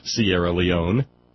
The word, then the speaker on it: Sierra Leone: Or maybe it's Sierra Leone, because I'm told that we do not pronounce the E in Sierra Leone.